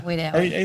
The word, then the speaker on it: Wedowee: Well, we drove from Wedowee today. – Oh, okay. Wedowee. Is that how you say it? Yeah. – Wedowee.